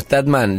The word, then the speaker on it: Statham: Y después hay una dando vuelta ayer que lo vi en tres personas, que es la de Jameson Statham.